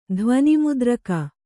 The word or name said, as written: ಧ್ವನಿಮುದ್ರಿಕೆ